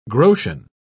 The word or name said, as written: groschen